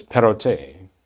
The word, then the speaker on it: Perrottet: He's become the premier of New South Wales in Australia, and his name is Perrottet. I understand that's the pronunciation, P-E-R-R-O-T-T-E-T.